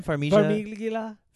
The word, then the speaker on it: Farmiga: Well, how do you say her name? – Farmiga.